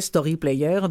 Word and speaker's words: Storyplayr: Alors, Story Player, comme ça se dit et comme ça se prononce avec en bon français, Story Player. Donc, Storyplayr.